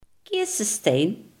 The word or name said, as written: kersenpit